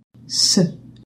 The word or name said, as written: c cedille